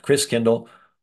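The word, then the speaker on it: Christkindl: which in Old German was pronounced Christkindle. Over the years, Christkindle got pronounced Kris Kringle.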